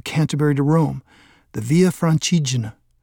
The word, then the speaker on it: Francigena: The name means roughly the way through France and is pronounced Francigena.